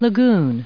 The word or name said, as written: lagoon